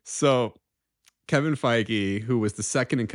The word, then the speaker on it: Feige: So Kevin Feige, who I don't know if we know, we have confirmed his last name is pronounced Feige, correct? – That's the only way I've ever heard it pronounced. – Right. – Okay. Because like, it would make me like a Fiji or something like that. – Yeah, or like Fage. I don't know.